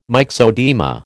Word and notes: myxoedema: American English

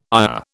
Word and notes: ire: British English

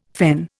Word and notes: fen: Indian

